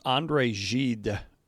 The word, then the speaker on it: Gide: And today's quote comes from a man, now I don't want to butcher his name, but the way I've seen it pronounced is André Gide, G-I-D-E is how you spell his last name, French author, Nobel Prize winner.